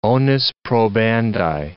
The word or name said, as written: onus probandi